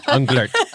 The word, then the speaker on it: Unglert: Unglert.